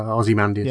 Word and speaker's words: Ozymandias: I can't remember how you pronounce his name. – Yeah, Ozymandias.